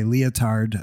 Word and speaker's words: Lyotard: Partly because though I've been reading some postmodern philosophers, so I've been reading a guy, Lyotard.